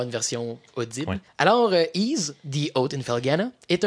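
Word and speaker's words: Ys: Ease, en passant, on dit Ease. C'est comme ça qu'il se prononce.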